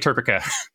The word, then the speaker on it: TERPECA: So to make sure that we have a pronunciation that works in all dialects, it is definitely TERPECA. – That's how I say it. So I agree that is indeed the correct pronunciation.